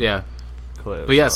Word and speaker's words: Kaleo: Kaleo.